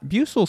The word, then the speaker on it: buccal: Buccal fat? – What? – B-U-C-C-A-L. How's that pronounced? – B-U-C-C-A-L? – Looks like buccal. Bucal. Bucal fat? – Bucal seems more correct.